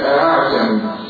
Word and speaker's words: أعجمي: الألف المسهلة في كلمة واحدة في القرآن كله اللي هي أعجمي أعجمي ماشي؟ أعجمي هكذا أعجمي أعجمي أعجمي أعجمي أعجمي أعجمي أعجمي هكذا ينطق بالهمزة بين الألف والهمزة. فقل لا أعجمي ولا تسهيل لحفص غيرها ولا تسهيل لحفص غيرها.